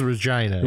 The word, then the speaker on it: Regina: but it's actually Regina, or maybe it's Regina. I don't know how to say it. I think it, it is Regina. – Regina? Yeah. I don't know. Sometimes you want a gene, sometimes you want a giant.